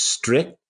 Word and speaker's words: streek: Well, I totally mispronounced that word. It was spelled, I think, Streek. I said streak, but actually it's strict.